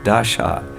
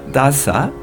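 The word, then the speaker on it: Dasha (दशा): It's with a long A, the last A. Dasha. It's not Dasa, that's being a servant.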